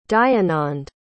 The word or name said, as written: dayanand